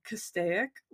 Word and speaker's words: Castaic: Near, and I'm going to butcher this name, Castaic Lake. – Yeah, that's how you say it.